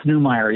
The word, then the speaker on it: Neumayer: It's Neumayer, yeah.